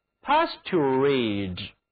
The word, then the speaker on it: pasturage: 这个词啊，发音挺难的，其中 t u 念作 to，接着需要你立刻把口型变化成 rage。 R-A-G-E 的发音，请跟我读 pasturage。